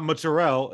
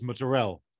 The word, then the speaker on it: mozzarella: It's not mozzarella, it's mozzarella, right? They drop a syllable.